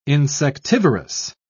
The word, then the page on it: insectivorous: British English